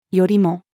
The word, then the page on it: よりも: female